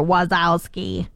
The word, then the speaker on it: Wazowski: Or Wazowski. Am I saying it wrong? – No, that was really good.